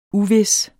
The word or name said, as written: uvis